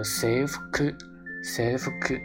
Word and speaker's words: 制服: 比如说制服，制服呢念作 safeku，safeku 啊，日本的这个制服呢其实多指。